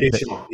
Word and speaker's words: Deschamps: And I don't know, help me pronounce Deschamps. Did I say it right? – Deschamps.